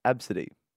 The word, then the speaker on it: Abcde: You might have seen this in the news at some point, but her name was spelt A-B-C-D-E, pronounced Absidy.